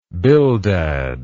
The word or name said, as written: bildad